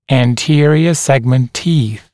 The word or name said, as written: anterior segment teeth